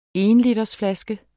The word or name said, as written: ENLITERSFLASKER